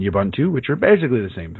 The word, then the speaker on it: Ubuntu: Well, if you go by the official pronunciation by the folks at Canonical, it's Ubuntu.